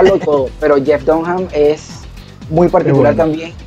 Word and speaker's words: Dunham: A los Jeff Dunham, por cierto, las personas que no han visto a Jeff Dunham, ahí la H no es muda. Se escribe D-U-N-H-A-M, ese es el apellido del ventríloco.